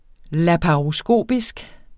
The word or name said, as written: laparoskopisk